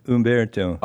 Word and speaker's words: Umberto: Well, they pronounce it. It's H-U-M, but you don't. If you'd hear it, there's a Umberto.